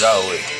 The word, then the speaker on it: Yahweh: And they say it spells out the words, the letters YHWH. And they say it's pronounced Yahweh.